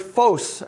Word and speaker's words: phos: That's the word phos, actually. In the Greek language, it's a long O.